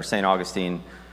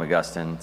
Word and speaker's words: Augustine: There's two ways to pronounce it. In seminary, they called him Augustine, so I don't know why, but the city in Florida is St. Augustine. So we'll call him Augustine because that's what my seminary professor said.